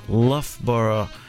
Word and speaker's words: Loughborough: And yeah, we just heard a few songs from one of Burnaby's sister cities, who I just learned the proper pronunciation of. It's Loughborough, right in the middle of England.